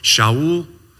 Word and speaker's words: Shaul: And so I can just imagine the parents of the Apostle Paul, 1,000 years later, and name him Saul, which in Hebrew you would pronounce Shaul.